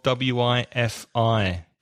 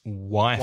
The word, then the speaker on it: Wi-Fi: Pronunciation of WIFI. Be careful. In English, it's Wi-Fi.